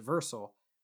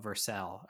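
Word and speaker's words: Vercel: We use GitHub Pages for, or actually we most recently moved from GitHub Pages to Versal, which is free, still for our uses, which is really nice. I think they call it Versal. I think that's the official pronunciation there.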